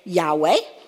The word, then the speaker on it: Yahweh: People have interpreted to say Yahweh. Other people have pronounced it Jehovah. So it's pronounced two different ways.